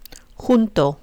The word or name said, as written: junto